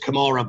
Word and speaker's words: Kamara: And then there was a, I know it's pronounced Kamara, but the joke is it was a camera finish for Fulham.